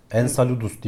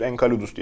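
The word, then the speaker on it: Enceladus: Ensaludus diye okunuyor. – Ensaludus, Enkaludus diye şansımı zorlayacaktım.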